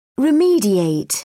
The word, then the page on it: remediate: British English